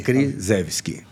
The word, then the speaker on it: Kriszewski: E também tem no, no, no individual, que é Jaime Antônio Krzyzewski. Boa. Como é que pronuncia é. esse nome polonês aí? Krzyzewski. Como se escreve, se pronuncia.